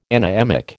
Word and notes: anaemic: American English

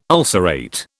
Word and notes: ulcerate: British English

